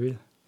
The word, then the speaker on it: Real: Et moi, je voulais juste aussi présenter, je sais pas si ça se prononce Real ou Real, oui. Mais... pardon. – C'est Real. C'est un anglais.